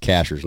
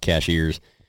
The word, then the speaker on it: Cashiers: That's pronounced Cashers, not Cashiers.